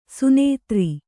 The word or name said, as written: ಸುನೇತ್ರಿ